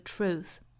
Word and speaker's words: truth: Immaculate 后面的这个字母 t 呢，把它省读 immaculate truth, immaculate truth. 那 truth 这个词的发音英式和美式都是一样的，所以我们都要注意里面的这个 t r 连在一起的发音是 ch r tr tr tr。一定不要把中间的这个 r 这个小的音给省略掉了哈，不要发成 true，应该是 true true true true true truth。